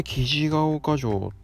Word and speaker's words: 雉岡城: 生地が丘城って読むんですよね。